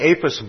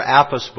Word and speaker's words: Apis: Well, if we look at the history of Egypt, and I have a slide of what's called the Apis bull, or Apis bull, it's pronounced both ways.